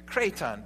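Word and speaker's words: kraton (κρείττων): and it's called kraton. That's how you pronounce it in Greek, kraton.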